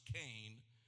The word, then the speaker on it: chen: And the word grace here in Genesis comes from the Hebrew word C-H-E-N, pronounced Cain, which literally means favor, kindness.